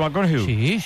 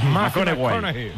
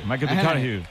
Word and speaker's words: McConaughey: Mateo McConaughey. – Sí. – Hay que ir corrigiendo la pronunciación. – McConaughey. McConaughey.